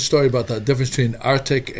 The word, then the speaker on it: RTIC: And you had this Arctic, and it's spelled R-T-I-C. – Arctic, yes.